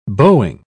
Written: bowing

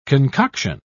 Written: concoction

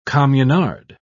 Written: communard

